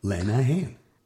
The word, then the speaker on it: Lenahan: My problem is nobody can spell my name, and it's very easy. Lenahan, you know, it's spelled like it sounds.